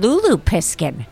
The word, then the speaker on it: Lulu Piskin: Tori Piskin, T-O-R-I-P-I-S-K-I-N. – And Lulu Piskin. – Wait, spell it. – L-U-L-U.